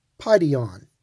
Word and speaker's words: paidion: It is interesting to note that the term young child is the Greek word paidion. Paidion, P-A-I-D-I-O-N, paidion, which means a young child from about the age of 2 to 12.